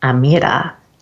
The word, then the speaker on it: Amyra: Now, my name is Amyra, but it's actually pronounced Amyra in Arabic, and it means princess.